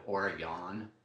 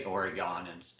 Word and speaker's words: Oregon: And I play around with the pronunciation of Oregon. – Oh, you do? Why? – Because a lot of people say Oregon instead of Oregon.